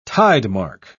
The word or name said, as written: tidemark